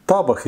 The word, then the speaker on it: Табах: Есть Антон табах или табах, я, к сожалению, не помню точно, на какой слог ударения ставить.